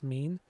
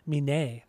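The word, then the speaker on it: Mene: It's M-E-N-E, so I assume it's pronounced Mean, but... Oh no, it's actually pronounced Mine.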